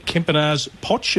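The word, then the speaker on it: Kempenaers-Pocz: Apologies if I don't pronounce it 100%, but Charlotte Kempinars-Potch, have I got that right, pronunciation? – You've done it very well. It's hard to get some names right, that's how it's pronounced.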